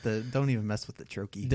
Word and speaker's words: troche: And a troche is a two syllable word or series of one syllables. That has a stress that actually sounds like the word troche. So it's that first stressed syllable and then an unstressed syllable.